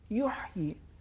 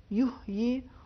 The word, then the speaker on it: یحیی: آیت نمبر 9 میں ایک لفظ ہے یحیی، اس میں حرف ہا، اس کا عربی میں ایک خاص تلفظ ہے جو وسط حلق سے ادا ہوتا ہے. دیکھیں یحیی اور یحیی دونوں میں فرق ہے.